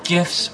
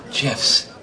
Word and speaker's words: GIF: Those little animated pictures on the internet, are they called GIFs or GIFs? – Well, the G stands for graphics. That's a hard G, so I say GIF. – The guy who invented it says it's GIF.